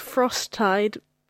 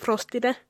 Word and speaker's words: Frosttide: It's Frosttide, or we used to pronounce it Frostide, because they couldn't speak English.